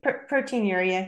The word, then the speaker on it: proteinuria: proteinuria. Is that right? – Yeah, proteinuria.